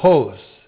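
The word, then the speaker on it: hos (ὡς): We start out with a comparative particle, hos. That's H long O-S, hos.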